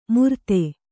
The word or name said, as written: ಮುರ್ತೆ